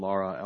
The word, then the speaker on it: Lara: My name is Roland Lara, L-A-R-A, on behalf of the petitioners.